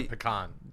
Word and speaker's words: pecan: By the way, pecan. – The cherry. – I pronounce it the way they do in the South. It's pecan.